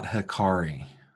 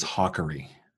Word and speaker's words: Hakari: So it is not Hakari. – Right. – It is Hakari.